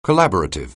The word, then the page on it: collaborative: anglais